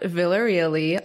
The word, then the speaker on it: Villareal: It's at Villareal. I'll spell that for you. V as in Victor, I-L-L-A-R-E-A-L-L-Y.